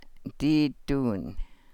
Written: dii duun

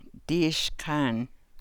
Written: dii sḵaan

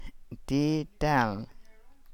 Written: dii daal

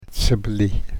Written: sablii

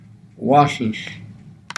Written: waahlahl

